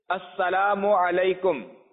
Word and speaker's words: السلام علیکم: سلام اور جواب سلام کا درست تلفظ یاد فرما لیجئے، جیسے ابھی میں کہتا ہوں آپ سن لیجئے اور دہرائیے، السلام علیکم، السلام علیکم، السلام علیکم.